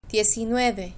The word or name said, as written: diezinueve